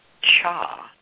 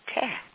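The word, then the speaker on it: cha (茶): In the most of the dialects of Chinese, it is pronounced cha or chai. But in the central coast of China and in Southeast Asia, it is pronounced ti or te.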